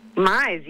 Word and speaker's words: Mize: I tell people it's like customize or minimize or maximize. That's the best way to remember it.